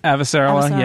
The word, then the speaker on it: Avasarala: How do I say it? I cannot say her name. – Avasarala, yeah.